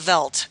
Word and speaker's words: Veldt: They are the garbage collectors and janitors of the Veldt, and that's spelled V E L D T. Um, it might have sounded like felt, the material, when Chris said it, but it's a large, open, living landscape.